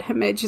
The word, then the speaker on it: Hemej: And it's called Hemej, which is actually written as HMD, but Hemej is how it's pronounced.